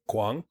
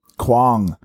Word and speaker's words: Quang: Quang. – Quang. – Ding Van Quang. – How do you spell it? Quang. – Q-U-A-N-G.